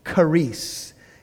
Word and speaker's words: charis: Okay, so in Greek, the word for grace is charis. It's spelled C-H-A-R-I-S, charis.